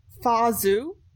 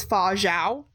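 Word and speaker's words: Fa Zhou: So my number four is Fazu from Mulan. Yes. That's how they pronounce it in the movie. – Yeah. – I saw other places it was Fa Zhao, so I'm like, I don't know.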